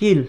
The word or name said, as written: GIOL